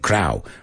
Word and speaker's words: Crowe: C-R-O-W-E. Pero no sé por qué muchos decís Russell Crowe, you say, I think, Crowe, right? It's Crowe, Crowe, como cuervo, más o menos, right? No, no, más o menos, igual. La E es muda al final, so Cameron Crowe, Russell Crowe.